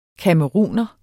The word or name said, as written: camerouner